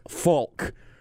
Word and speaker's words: folk: I will say that I enunciate the L in words like folk. – Folk. – Folk. I basically pronounce it as if it's F-A-L-K.